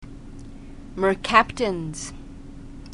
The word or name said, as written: mercaptans